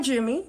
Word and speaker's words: Jummy: I go by Jimmy, J U M M Y, because I've begun, you know, beginning to notice people actually misspell it.